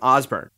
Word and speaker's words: Osborne: You've almost surely heard Coach's name pronounced Osborne as well as Osborne. And from what I understood from his outgoing phone message, Coach actually pronounces his last name Osborne. And so for that reason, I've chosen to pronounce his last name Osborne for these two episodes.